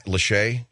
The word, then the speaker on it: Lachey: Lachey? Is that it, bud? – Yeah. It rhymes with Faye.